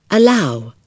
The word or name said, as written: allow